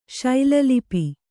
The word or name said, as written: ಶೈಲಲಿಪಿ